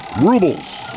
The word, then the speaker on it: rubles: Ruples? I don't know. – Close. Rubles.